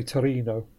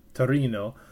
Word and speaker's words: Torino: They say Torino. – Torino.